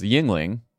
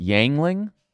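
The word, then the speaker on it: Yuengling: Yingling. And he called it Yangling.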